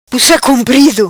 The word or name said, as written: Pussa Comprido